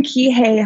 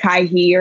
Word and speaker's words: Kihei: I live in Kihei, Hawaii, so that's specifically in Maui. It's probably good. Most people say like Kaihi or something like that.